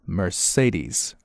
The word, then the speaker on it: Mercedes: 这个读音大家注意一下啊，首先呢前面这个梅赛德斯怎么读呢，它是读成 Mercedes, 啊它中间有三个 E 字母啊，但是读的都不一样。第一个 E 呢是就是发那个 R 的音啊 ,Mer, 然后第二个音呢发这个 A, 啊双元音。 A, 然后第三个呢发 E,、啊、所以是 Mercedes,